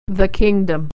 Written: the kingdom

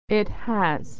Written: it has

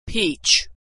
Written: peach

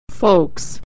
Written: folks

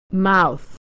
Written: mouth

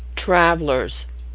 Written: travelers

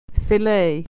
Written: fillet

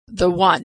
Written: the one

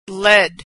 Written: led

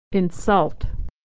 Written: to insult

